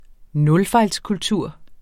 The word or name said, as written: nulfejlskultur